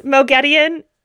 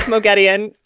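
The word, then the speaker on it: Moghedien: This is Mogedion, or Mogedine, depending on how you pronounce her name and or which version of the audiobooks you were listening to. – Mogedion. – I like Mogedion, the pronunciation that makes sense.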